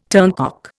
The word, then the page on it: turncock: Indian